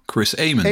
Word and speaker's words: Chris Amon: Te weten Nick Heidfeld, Stefan Johansson, Chris Amon, zeg ik dat goed? – Chris Amon. – Amon. Chris Amon, yes, yes. – Recht aan, als je de zitteraars mag geloven, zal het wel Amon moeten zijn.